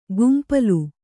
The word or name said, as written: ಕಂಪಳ